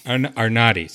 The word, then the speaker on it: Arnade: Arnade, sorry.